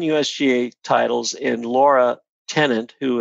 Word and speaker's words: Lara: It's L-A-R-A. – Yeah, but she pronounces it Laura.